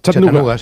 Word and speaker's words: Chattanooga: Chattanooga, sí.